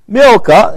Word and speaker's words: Milcah: And it came to pass after these things that it was told Abraham, saying, "Behold, Milcah," it's pronounced M-I-L, slash or dash, K-A-H. C, there is a K sounding.